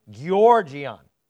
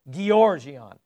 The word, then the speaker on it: georgion: Georgion. G-E-O-R-G-I-O-N, with an accent on the first E. It's georgion. So one is a G sound, one is a J sound, but it's georgion.